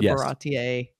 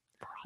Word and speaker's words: Baratie: But so this is only the first half of the Baratie. Look, I said it right. – Baratie. Yes.